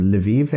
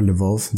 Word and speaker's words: Lviv: My dad's grandfather, so my great-grandfather, left Ukraine in the early 1900s, and he was from Lviv area, so the west, far west. – Mm-hmm. – Or Lvov, depends how you want to pronounce it.